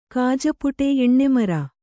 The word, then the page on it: ಎಣ್ಣೆಮರ: Kannada; eṇṇemara